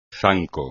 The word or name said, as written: zanco